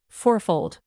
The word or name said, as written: fourfold